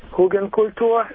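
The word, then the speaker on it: Hügelkultur: Actually, I know something about Hugenkultur, is the way it's pronounced in German.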